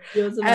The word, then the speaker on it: Yosemite: Wait, you said Yosemite? Yeah, because and I Google and they're like, that is not how you say it.